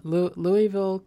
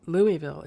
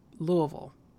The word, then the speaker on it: Louisville: Louisville, Kentucky is not pronounced Louisville. It's Louisville. It's Louisville. – Louisville. It's Louisville. And they know you're not from down there if you pronounce it any other way.